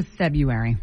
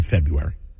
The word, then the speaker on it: February: And by the way, we learned today, just to clear it up for everybody, that that R, in this first R, is silent in February. It's February, not February.